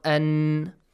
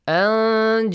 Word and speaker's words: أن: I heard someone clearly pronounce the noon sound. Not أن. أن جاءه الأعمى.